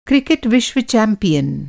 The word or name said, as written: चैंपियन